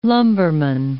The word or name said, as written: lumberman